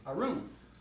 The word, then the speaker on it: arum: And the Hebrew word is arum. Spelled the same way,